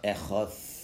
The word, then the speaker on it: Echad: But if you know from ancient Sephardic ones, the Dalid, when it doesn't have a dot, is pronounced as a TH. So really it's pronounced Echad.